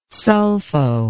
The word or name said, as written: sulfo